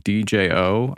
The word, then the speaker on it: Djo: The guy from Stranger Things who plays Steve, his name's Joe Keery. He has this band called DJO. I think it's just called Joe. The D is silent for, you know, reasons.